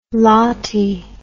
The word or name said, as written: lathee